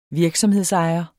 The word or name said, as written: virksomhedsejer